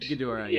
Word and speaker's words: Ghidorah: I'm assuming Ghidorah is pronounced correctly. Yeah, that's correct. – Ghidorah.